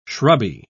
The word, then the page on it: shrubby: British English